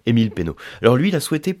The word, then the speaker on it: Peynaud: Euh, qu'est-ce que je peux citer d'autre Ben l'influence aussi d'Émile Pénaud. Donc il faut absolument connaître hein, en tant que, dégustateur. Donc Pénaud, ça s'écrit P-E-Y-N-A-U-D.